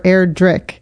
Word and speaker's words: Erdrich: I think our BookTube, I was like, is it Erdrich or Erdrich? It's Erdrich, like the hard K, even though it's spelled with a CH.